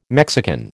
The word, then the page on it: Mexican: American English